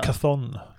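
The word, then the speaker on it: Chthon: Chaton, tror han heter. – Cathon. Cathon, tror jeg det skal ja. uttales. Men,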